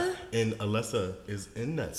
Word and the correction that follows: Alessia: Alessia? – She's in this song. Alessia is in that song.